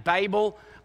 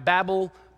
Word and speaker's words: Babel: Now, for the rest of the sermon, I'm going to keep saying Babel, and you're going to wonder if I'm talking about Babel. I am. You're saying it wrong, I'm saying it right. This word really is a situation where this is actually how we say it in Australia, the Tower of Babel.